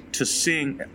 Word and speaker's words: Tsingtao: Tsingtao. – Sorry about that, Asia. It's Tsingtao, all of Asia, not just China.